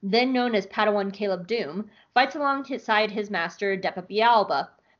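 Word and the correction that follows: Billaba: Balaba. I always mis-say her name. Balaba. I always want to say Bialba. It's not. It's Balaba.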